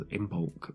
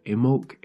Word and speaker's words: Imbolc: It often gets mispronounced as Imbolc, but Imbolc is the old Gaelic Irish way of saying it.